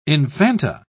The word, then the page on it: infanta: British English